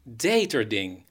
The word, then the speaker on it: Deterding: Hoe zou je dit uitspreken? Deterding. – Deterding. Een Nederlandse meneer, die heette Deterding.